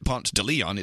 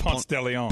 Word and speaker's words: Ponce de Leon: But you really don't pronounce it Ponce de Leon. It's pronounced Ponce de Leon. – Ponce de Leon. But if you live in Atlanta, you say Ponce de Leon.